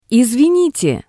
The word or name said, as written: Isvinite